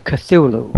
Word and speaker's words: Cthulhu: Well, I pronounce it Cthulhu.